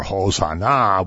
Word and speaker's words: Hosanna: And so the people, as we're going to see in a minute, start to cry Hosanna, or Hosanna, which means come and save us. And if you put the accent on the last syllable, it actually means come and save us now.